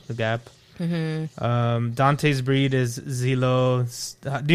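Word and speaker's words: Xolo: The X is kind of like a like an S. Zolo, like that.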